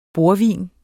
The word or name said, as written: bordvin